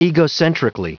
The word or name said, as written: egocentrically